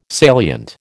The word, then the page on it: salient: American English